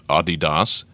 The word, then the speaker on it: Adidas: And in Germany, Adidas, or if you prefer the European pronunciation, Adidas, has dodged a legal threat to one of its best-selling tennis shoes.